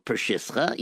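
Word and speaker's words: Przysucha: Pershiska. I think I'm pronouncing it right now. – I think so. Pershiska. Yes, that's the correct pronunciation.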